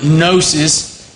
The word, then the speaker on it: gnosis: Gnosticism is from the Greek word gnosis. In the New Testament, knowledge is the translation of gnosis, or a similar word. The G is silent just as K is silent, and the word know, or knowledge.